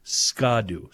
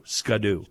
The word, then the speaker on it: Ski-Doo: The proper Midwest pronunciation, by the way, of Ski-Doo is Ska-Doo. Ska-Doo.